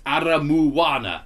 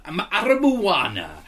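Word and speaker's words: Aramoana: town of Aramona, pronounced Aramuana. Aramuana.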